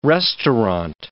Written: restaurant

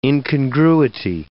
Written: incongruity

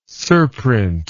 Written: surprint

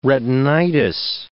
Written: retinitis

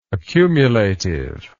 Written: accumulativeness

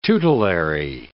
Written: tutelary

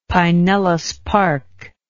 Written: Pinellas Park

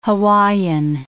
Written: Hawaiian

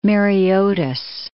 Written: Mareotis

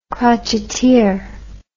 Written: crotcheteer

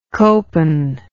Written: copen

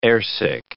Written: airsick

